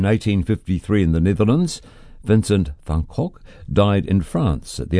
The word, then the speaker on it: van Gogh: Now, there are various pronunciations. The Dutch pronunciation sounds a bit like Van Gogh, but the English version sounds more like Van Gogh or Van Gogh. Take your choice.